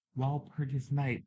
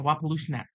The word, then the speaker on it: Walpurgis Night: Walpurgis Night, that's how you say it. – Mm-hmm. – Why was I saying a Walpolushnak? Walpolushnak. That's how I used to say it.